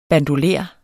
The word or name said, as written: BANDOLER